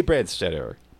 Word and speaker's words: Brandstetter: And our special guest today, Jay Brandstetter.